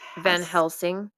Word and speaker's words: Van Helsing: Van Helsing.